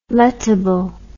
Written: lettable

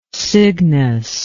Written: cygnus

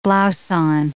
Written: blouson

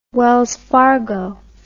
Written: Wells Fargo

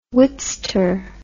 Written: witster

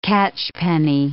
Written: catchpenny

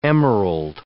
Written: emerald

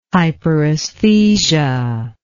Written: hyperaesthesia

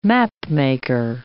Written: mapmaker

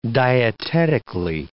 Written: dietetically

